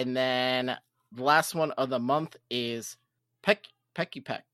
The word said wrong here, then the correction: Pikipek: No? I said it wrong? Pikipek? – There you go. Pikipek.